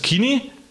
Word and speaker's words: Kini: König sagt man ja auf Hochdeutsch, gell? – Oder so. – Und auf Bayerisch spricht man es Kini.